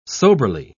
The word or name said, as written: soberly